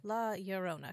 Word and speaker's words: Llorona: La Llorona, because it's two L's.